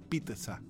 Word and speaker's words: pizza: Pero en lo que pones la masa con el queso arriba no se dice pizza con X. Se dice pizza con T. Esa es la correcta pronunciación de la doble Z en italiano. Es pizza, pizza.